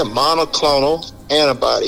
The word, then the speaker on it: monoclonal antibody: I can't even pronounce it. How do you say it? – Yeah, monoclonal antibody.